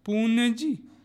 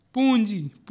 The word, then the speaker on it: पूंजी: इसी तरह पूंजी, पूंजी अगर उसमें अनुस्वर लगाएंगे तो उसका उच्चारण क्या हो जाएगा, पुण्य, पुण्यजी, न आ रहा है, जबकि उसका शुद्ध उच्चारण होगा पूंजी, पूंजी। इसी तरह अंजलि,